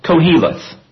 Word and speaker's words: Koheleth: Ecclesiastes is from a Hebrew word, and I'm going to pronounce it English-wise, the way it looks to me at least, Koheleth.